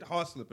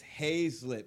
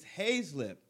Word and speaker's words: Hazlip: No, she said it wrong. She said "Hawslip" or something like that. Right. Mm-hmm. I said, "Excuse me, Miss whatever her name was at the time." I was like, mm-hmm. "Can you come here for a second?" She's like, "Okay." And I'm like, "So." I hear when you say her name, you're saying it wrong, incorrectly. – Right, right. – Like, it's Hazlip.